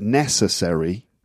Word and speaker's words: necessary: So when you get a word like necessary, the stress is on the first syllable.